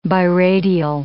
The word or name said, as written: biradial